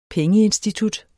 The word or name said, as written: pengeinstitut